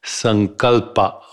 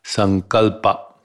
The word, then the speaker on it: sankalpa: It is found in a sutra that is properly pronounced as sankalpa or sankalpa, sankalpa.